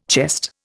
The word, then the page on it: chest: Indian